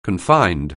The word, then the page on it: confined: anglais